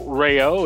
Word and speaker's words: Rao: My name is Joe Rao. It is spelled R-A-O, but it is pronounced as if there were a Y between the A and the O.